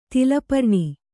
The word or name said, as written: ತಾಲಪರ್ಣಿ